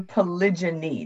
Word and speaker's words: polygyny: So in reference to polygyny, did I say it correctly? – Correct.